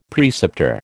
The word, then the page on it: preceptor: American English